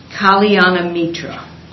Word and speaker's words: Kalyanamitra: And again, these words in Sanskrit, they are actually pretty easy to pronounce if you just divide them into syllables, which you may not know where they are in syllables, but so it's Kalyanamitra. Kalyanamitra.